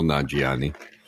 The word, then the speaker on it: Nanjiani: I might add, by the way, that I mispronounced Mr. Nanjiani's name. It's Kumail Nanjiani.